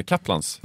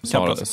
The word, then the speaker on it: Kaplans: Kan du hjälpa mig med uttalet här? – Eh, Kaplans, Kaplans sa, ja.